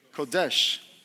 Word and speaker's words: kodesh: Kadoshi. – It's this word, kodesh.